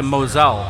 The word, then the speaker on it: Mosel: Did I say that right? Moselle? – Mosel. – Doesn't it sound like pussy or something, though, if you say it wrong? – It's Mosel. – Mosel. That means pussy? – Yeah, that's without the L and with the umlaut, Mosel.